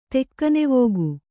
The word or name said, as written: ತೆಕ್ಕನೆವೋಗು